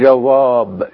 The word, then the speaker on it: جواب: طب لو انا جبت كلمة جواب قبل كلمة دادا، جواب، وهننطقها بالطريقة بقى الفارسية اللي احنا لسه واخدين بقى الابجدية دلوقتي، الجيم هتتنطق معطشة، والواو هتتنطق زي حرف الفي، والالف هتبقى مفخمة. فعشان كده بقول جواب، جواب، جواب دادا.